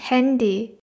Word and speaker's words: Handy: なので、あーの発音で読みたくなるんですけど、なぜかこれはあーじゃなくて A の発音になります。 das Handy ですね。